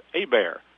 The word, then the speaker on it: Hebert: I'm going to tell you what, the two young folks that came and hunted with us, Jake, now most people would say Jake's name is Jake Hebert, H-E-B-E-R-T, but if you're a Cajun, that is pronounced Abear.